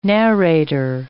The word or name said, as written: narrator